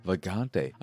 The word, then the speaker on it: Vagante: This episode is all about Vagante. I know what you're thinking. It's pronounced Va-Gan-Te. I assure you it is not pronounced Va-Gan-Te.